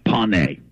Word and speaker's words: Pone: Yeah, my first name is Corn, and my last name is spelled P-O-N-E. – P-O-N-E. – Yes. – Corn pony? – Yeah, pony. – Pony. – Yeah. A lot of people mispronounce it and say corn pone, but, you know, that's wrong.